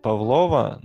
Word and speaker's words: Павлова: называют Павлова, но потому что они не знают, как правильное ударение ставить.